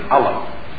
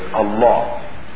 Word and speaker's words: Allah: Most non-Muslims, Westerners or Easterners, are familiar with the idea that Muslims call their God Allah, or it's usually pronounced in the West as Allah. We pronounce it actually as Allah.